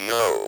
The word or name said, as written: know